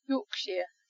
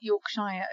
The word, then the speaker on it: Yorkshire: but it's not pronounced shire, it's shire. So York, Yorkshire. It's not Yorkshire.